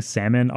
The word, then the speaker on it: salmon: I would always pronounce the L in salmon. I was like, why is there an L here if I'm not supposed to pronounce it?